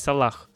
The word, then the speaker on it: Салах: Салага, Салаг, да, что Правильно говорить Салах. Салах.